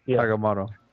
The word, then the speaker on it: Agamotto: But afterwards, once he gets the eye of was it Agamotto? I probably pronouncing that right. – Yeah. Agamotto.